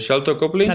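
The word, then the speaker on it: Sharlto Copley: Entre el reparto se encuentra, no sé cómo se, cómo se, cómo se lee, Sairi Copley, no sé si lo he dicho bien. – Eh, Shalto Copley. – Shalto Copley, exactamente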